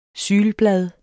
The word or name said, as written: sylblad